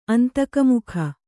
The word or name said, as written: ಅಂತಕಮುಖ